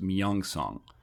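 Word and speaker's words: Myeongseong: So we now know with solid proof that the Japanese assassinated the Korean Empress Myeon Sung-gon. I think I'm pronouncing that right. Do you see that? – Myeong-sung. Yeah. The Korean Empress Myeong-sung.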